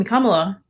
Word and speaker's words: Kamala: Uh, I think the come come Kam- excuse me Kamala. I've been pronouncing her name so many times. Kamala, seeing her um, take get sworn in, um, to be able to.